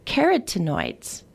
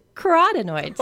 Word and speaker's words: carotenoids: And I'm so happy that I did, because I have always thought the way this word is pronounced was carotenoids. – Yeah, it's, I probably would have said it like that. – Turns out it's carotenoids.